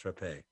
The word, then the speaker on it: Rappe: By the way, her name was Rappe. It's pronounced Rappe.